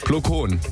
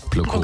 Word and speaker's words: Plo Koon: Plo Koon. – Plo Koon. – Plo Koon. P-L-O, neues Wort, K-O-O-N.